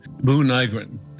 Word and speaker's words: Buu Nygren: The moon holds a sacred place in Navajo cosmology, said Navajo President Boo Nygren. It's spelled B-U-U-N-Y-G-R-E-N.